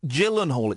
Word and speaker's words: Gyllenhaal: And Harry, 23, has overtaken dimpled celebs such as movie stars Brad Pitt and Jake Gyllenhaal. It's a soft G.